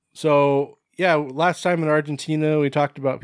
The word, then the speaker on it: Argentina: With no accent whatsoever, they just changed the G to an H? – Yep. Ask them. – Argentina.